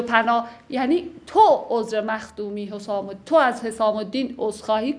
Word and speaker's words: حسام: این نکته بگم، حسام تلفظ عربیش با ضمه است، یعنی حسامه به معنای شمشیر، ولی خب رفته رفته تو زبان ما شده حسامی.